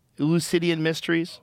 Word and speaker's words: Eleusinian Mysteries: Eleusinian Mysteries? Oh, yeah. Yes, that's how you say it.